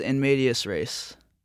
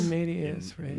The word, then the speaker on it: in medias res: And is that how you pronounce that? – Yes. – In medias res. In medias res. – There were multiple pronunciations. I think that's one of them, though.